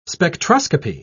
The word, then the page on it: spectroscopy: British English